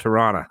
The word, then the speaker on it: Toronto: No, you know you're saying it wrong, right? No, is it called Toronto? No, it's called Toronto. – Yeah, it's Toronto. There's no second T in that.